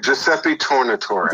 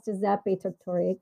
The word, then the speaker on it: Giuseppe Tornatore: I think his name is Tortore, yeah. – I'm not sure how to pronounce his name, but it's a great film. – Yeah. Okay, so perfect. So I'm looking at it as they still... – Giuseppe Tornatore. – Giuseppe, yes, Giuseppe Tornatore.